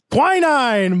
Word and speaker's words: quinine: Quarinine? – Oh, boy. You're almost there. You just said it wrong. – Quinine? – Quinine. Quinine.